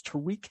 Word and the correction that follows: Taraji: Henson is her name, um, and Taraji Henson. I guess is how you pronounce her first name.